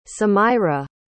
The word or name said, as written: samaira